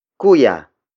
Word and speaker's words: kuya: The next word is kuya. Kuya. Kuya. Kuya is spelled as K-U-Y-A.